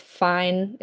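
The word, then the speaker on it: Fiennes: It's really, really weird reading the book after watching the movie, because, like, in the movie, he's played by Hero, I think it's Fine is how it's pronounced.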